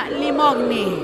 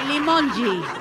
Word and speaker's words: Limongi: Por ahí escuché a alguien que decía Virginia Limogni, no se dice Virginia Limogni. Se dice Virginia Limongi.